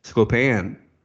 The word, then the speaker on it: Sklopan: Have we heard of pronounce Sklopan? – I don't know. – Sklopan. – Sklopan. – Like Chopin.